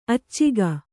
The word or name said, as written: ಅಚ್ಚುಗ